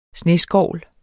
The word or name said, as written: sneskovl